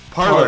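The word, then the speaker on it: Parler: I'll make us a Parlay account, and we're gonna be the most hated account on Parlay. – Yes. – Parlor. I didn't even say it right, dude.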